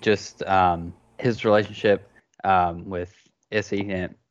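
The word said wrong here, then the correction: Essi: or what's her, sorry, I'm mispronouncing her name. Issa? – Essie.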